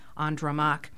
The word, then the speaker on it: Andromaque: It's dramatized by the French playwright in the 17th century, French playwright Jean Racine, hence the French pronunciation of Andromaque.